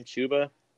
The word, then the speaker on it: Chuba: Chuba Hubbard, how do you say his damn name? Chuba? – Yeah.